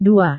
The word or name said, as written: dua